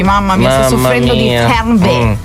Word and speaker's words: Fernweh: Avendo un attimo parlato di questo prima della pubblicità, vi ho anticipato che avremmo parlato di una parola tedesca che si chiama Fernweh. – Cioè, la pronuncia è questa? – Sì. Fernweh.